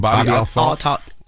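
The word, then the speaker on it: Bobbi Althoff: and you had some words about his interview with, how you pronounce his name? – Bobby Altoff. The podcaster.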